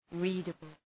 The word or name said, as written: readable